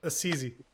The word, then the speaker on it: Assisi: How do you pronounce it? Assisi. – Assisi. I thought you were making. – No, that's how I've always heard it pronounced.